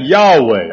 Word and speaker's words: Yahweh: We accommodatively pronounce that Yahweh, although we really don't have a phonetical way of saying that.